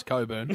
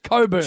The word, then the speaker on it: Cockburn: I don't care how you spell it. It's pronounced Coburn. – How do you spell it? That's a question. – No. – C-O-C-K.